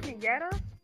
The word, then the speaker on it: Kenyetta: Is it Kenyetta? – Yes.